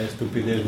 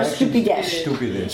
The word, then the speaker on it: estupidez: In Italian it's stupido, and here it's stupidez. – The person is stupido. – This person is stupido, yes, and the actions are stupidesh.